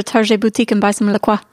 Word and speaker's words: LaCroix: And they say LaCroix rhymes with enjoy.